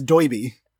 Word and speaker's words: Doiby: I think we need to talk about the fact that there's a man named Doiby in this, and I feel like his name should be Derby, but, like, and I thought that's what it was. I thought they were just writing it phonetically. No, his name is Doiby, and he talks like his name, like he's saying his name is Derby.